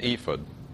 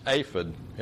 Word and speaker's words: ephod: What on earth is an ephod? It's actually pronounced aphid in Hebrew, but that sounds like an insect to me, so I've chosen to use the English pronunciation here.